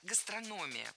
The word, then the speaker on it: гастрономия: У нас гастрономия тоже развивается стремительно, как и в других регионах. – Не гастрономия, а гастрономия. Ударение в этом слове падает на третий слог.